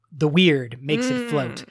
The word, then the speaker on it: wyrd: And that is known as the weird. Again, spelling, not as you think it, W-Y-R-D. There's probably a better pronunciation as well.